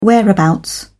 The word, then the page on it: whereabouts: British English